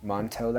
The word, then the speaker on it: Montou: Montou like a toe.